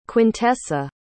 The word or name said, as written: quintessa